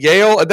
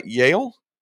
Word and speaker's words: Yael: And Yael, that is how you pronounce your name, right? Yael? – Yael, yeah.